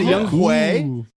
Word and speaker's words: Younghoe: Oh, is it Younghoe? – That's how I heard it pronounced by, I believe, Chris Hansen on Red Zone, and I feel like that's probably more accurate than the most trusted name in football.